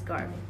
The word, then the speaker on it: Garvey: Marcus Garvey.